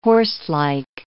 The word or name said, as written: horselike